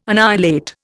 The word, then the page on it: annihilate: Indian